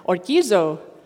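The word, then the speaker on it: orgizo (ὀργίζω): This one I know is pronounced correctly because I pressed the little sound thing on the computer and then orgizo. So this one's right.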